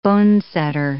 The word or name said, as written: bonesetter